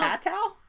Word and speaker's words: Apatow: Apatow? It's like a patasaurus. – How do you say his name? – Apatow. – Is that how you say his name? – Yeah.